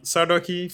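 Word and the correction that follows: Sarkodie: for sure. – Sakodi, from Ghana. – I always say his name wrong. – Yeah, Sakodi, yeah, from Ghana. – He's always calling him Sardoki. Sakodi, for sure, from Ghana.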